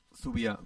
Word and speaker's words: zubia: Pariseko Zubaya. Zubaya no. ¿Cómo se dice puente? – Zubia. – Zubia, me he liado.